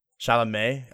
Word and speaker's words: Chalamet: You have Timothy Chalamet. Is that how you pronounce that, bro? – Chalamet.